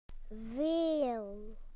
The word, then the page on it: a lot: British English